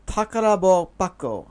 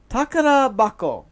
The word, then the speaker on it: Takarabako: This is a game called Santa Claus no Takarabako. Oh, I said it wrong. Takarabako. There we go.